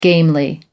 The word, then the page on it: gamely: American English